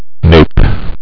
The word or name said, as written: nape